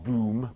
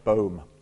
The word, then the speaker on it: ten Boom: That's the correct pronunciation, not Boom, but Boom.